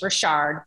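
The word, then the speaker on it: Rich: My last name is pronounced Richard, but we should have so it's French, just like Sanofi is French.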